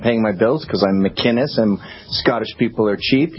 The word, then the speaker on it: McInnes: My name is Gavin McInnes. Not McGinnis, McInnes. It's a hard C.